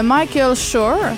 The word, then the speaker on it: Michael Schur: Donc, The Good Place, c'est une série de Michael Schur.